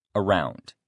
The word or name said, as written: around